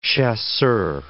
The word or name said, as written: chaussures